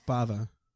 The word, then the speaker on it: Bava: I mean, my entire life, I think I kept saying Brava. – Yeah, yeah. – And then, you know, you watch a movie once and you're like, oh shit, it's Bava.